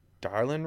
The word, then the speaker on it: Dylan: I'm gonna start with I can't say it's spelt Dylan, but I think it's Darlin, right? – Yeah.